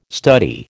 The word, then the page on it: study: American English